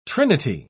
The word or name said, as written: trinity